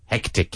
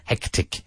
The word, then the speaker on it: hectic: So the first syllable, heck. – Heck. – Tick. Tick. Yeah, like tic-tac. Hectic. – Hectic. – Hectic.